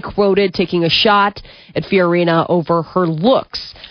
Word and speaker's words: Fiorina: Fiorina.